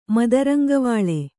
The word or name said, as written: ಮದರಂಗವಾಳೆ